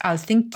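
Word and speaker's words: Alþingi: And in Iceland, of course, you've still got the, it's written as Althing, but I think it's pronounced Althingi.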